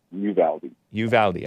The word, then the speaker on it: Uvalde: So that's Uvalde? – Uvalde.